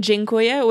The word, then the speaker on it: dziękuję: One thing I have to say, though, before we get to the pool scene, he thanked the swinging couple by saying dziękuję, which is what Borat would say. And that is how you say thank you in Polish.